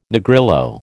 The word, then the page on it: Negrillo: American English